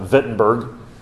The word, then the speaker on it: Wittenberg: That's with a W, but that's how they pronounce it, Wittenberg.